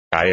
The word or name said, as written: caer